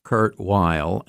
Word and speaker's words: Kurt Weill: At the time of his death, Kurt Weill, and I'm using the American pronunciation of his name because he wanted to be an American very much, and that's why he was writing a musical about Huckleberry Finn.